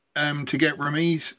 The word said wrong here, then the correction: Raimi: Raimi is how you pronounce it. Yeah. – Rami. – Just in case we ever interview him, it's Raimi.